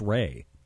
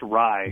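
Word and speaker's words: Rei: Sox have got a couple good ones, they think, in Swihart and Vasquez, and yet still went out and got young Austin, I believe it's Ray. – It's actually pronounced Rye, which I only remember because of the alcohol, which is terrible.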